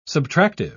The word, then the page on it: subtractive: British English